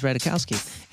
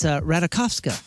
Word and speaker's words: Ratajkowski: Emily Ratajkowski, we thought it was Ratajkowski. It's Ratajkowska.